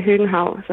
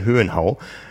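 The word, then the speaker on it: Høgenhaug: Es gibt natürlich auch regionale Unterschiede, auch in einem kleinen Land wie Dänemark, aber die meisten würden tatsächlich Christian Hüdenhauk sagen. Also das G schon mit aussprechen, aber nicht zu sehr betonen.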